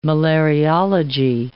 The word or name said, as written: malariology